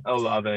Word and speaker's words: Olave: Is it Olave? – Olave, yeah. – Yeah, so that's not Olave.